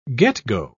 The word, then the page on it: get-go: British English